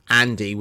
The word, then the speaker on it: Andi: And so I said, Andy with an I. Of course, my name is spelled A-N-D-I.